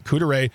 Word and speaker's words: Couderay: But now let's turn to Couderay. As I was told, that's how you say it, Couderay, to, it's a house, it's a tourist attraction that I'm not surprised exists with, because of Wisconsin's proximity to Chicago.